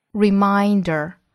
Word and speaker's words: reminder: reminder, reminder, 注意第二个音节的发音 mine, mine, reminder, to visit and care for the graves of family and friends.